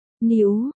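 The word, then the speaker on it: niu (牛): chữ niu phát âm là niu, tức là trâu hoặc bò.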